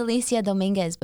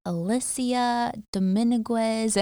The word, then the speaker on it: Alyssia Dominguez: It's Alyssia Dominguez, but people, I've gotten like Alyssia Dominguez and all these goofy ones.